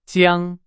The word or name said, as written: 江